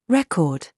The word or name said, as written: RAW